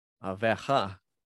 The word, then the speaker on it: Oliveira: If Dustin Poirier destroys Charles Dubronx Arvela, Aveja, because they pronounce their R's like an H in Portuguese in Brazil.